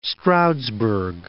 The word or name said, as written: East Stroudsburg